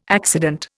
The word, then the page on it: accident: Indian